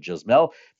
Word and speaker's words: Yasmel: And it's spelled Y-A-S-M-E-L. How do I know that it's not Yasmel, but Jasmel?